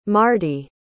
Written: mardi

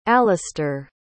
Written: alistair

